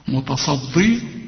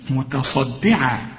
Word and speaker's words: متصدعة: انا عاوزك تكون دقيق قوي في نطق هذه الكلمة، لأن عندك صاد وبعدين عندك دال. الدال هتضيع منك إذا ما طلعتهاش دال خالص، هتطلع ضاء. قل لي متى، ما تقولش متى غلط، متصدي غلط، متصدي متصدعة من خشية الله.